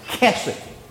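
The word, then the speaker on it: kesed (חֶסֶד): It looks like in English, H-E-S-E-D. It looks like hesed, but it's pronounced kesed.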